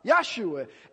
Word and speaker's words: Yeshua: But really what the name is, it's Yeshua, as you know, Yahshua.